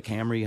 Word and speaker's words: Camry: So we get the Camry hybrid. Did I say that wrong? – No, no.